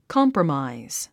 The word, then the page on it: compromise: kɑ’mprəmàiz; コンプロマイズ